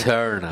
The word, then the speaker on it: Eternal: İşte böyle böyle Doom Eternal, e, et, Eternal nasıl? – Şey, Eternal.